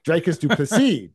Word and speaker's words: Dricus du Plessis: And then Robert Whittaker, always a contender there in his division, taking on the rising DDP and Dreas Duplessis, right? But did I pronounce that right? – Dreykus Duplessis. – Dricus du Plessis, DDP.